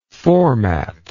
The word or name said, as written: formatter